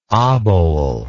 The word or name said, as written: obole